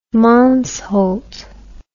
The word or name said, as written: Mansholt